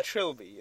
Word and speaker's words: trilby: A trilby.